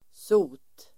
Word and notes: sot: [so:t]

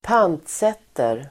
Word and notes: pantsätter: ²p'an:tset:er

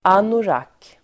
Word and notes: anorak: ['an:orak]

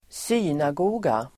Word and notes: synagoga: [²s'y:nago:ga]

